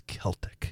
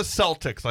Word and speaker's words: Celtic: And you know it's true Irish when you get the name right because it's Celtic. – Yes. – It's not the Celtics like in Boston. It's Celtic. It's true Irish.